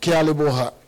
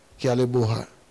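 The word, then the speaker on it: Ke a leboga: But in Twana, how do you say Giabonga? No. Kialeboha. Okay. Kialeboha. But hey, Jesus, we need bread now.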